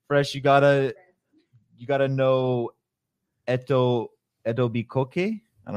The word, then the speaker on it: Etobicoke: It's pronounced Etobicoke, and it is between Mississauga and Toronto.